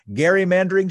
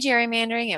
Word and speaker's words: gerrymandering: I say gerrymandering. It was from Eldridge Gerry, but I go with the popular pronunciation, which is gerrymandering.